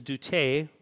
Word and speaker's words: deute: And the Greek word that is used here for follow is doute. And it is spelled D-E-U-T-E, but the Greek pronunciation is doute, which kind of sounds like duty, but means to come here, it means to come on or to come hither.